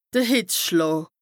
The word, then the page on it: de Hìtzschlàà: Bas-Rhin; Strasbourg